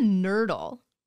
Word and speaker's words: nurdle: What's a nurdle? – Oh, great question. You pronounced it 100% correct.